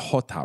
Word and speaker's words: Jota: And I'm sorry, it's not Jota, right? It's Jota. In Spanish, it's Jota.